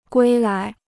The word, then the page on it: 归来: guī lái